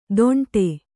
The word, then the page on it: ದೊಂಡೆ: kannada